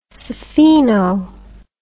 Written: spheno

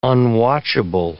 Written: unwatchable

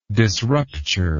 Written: disrupture

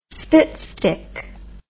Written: spitstick

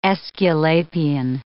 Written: Aesculapian

